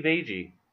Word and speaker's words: Agee: A-G-E-E. Edgy. – Oh, Steve Agee. – Agee, yeah. Okay, there we go.